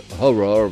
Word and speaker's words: HO99O9: Voilà, Horror qui s'écrit H-O-9-9-O-9, mais ça se prononce Horror, voilà, tout simplement.